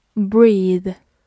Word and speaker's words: breathe: 最后我们拓展一下，如果在它的末尾加上 e，就变成 breathe。注意发音发生了很大的变化。它是它的动词，表示呼吸，breathe。